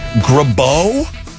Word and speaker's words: Girbaud: Grabeau? How would you say that? – Spell it. – G-I-R-B-A-U-D.